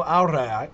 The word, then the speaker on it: aurae: You might not know aurai, but it's spelled A-U-R-A-E. It's where we get the word aural, as in sound, A-U-R-A-L.